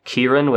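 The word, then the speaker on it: Kirin (キリン): So in Japanese, it's more like Kirin, with, like, it's spelled with a K. And in Korean, Girin.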